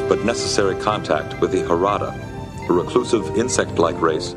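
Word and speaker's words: Jarada: I pronounced it so wrong. – Idiosyncratic attitude toward protocol. – How would you have done it? – A precise greeting. – I said Gerada as I was reading.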